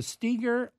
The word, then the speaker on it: Steger: And by the way, Steger is spelled S-T-E-G-E-R. So there's only pronounced Steger, but it's with one E, S-T-E-G-E-R.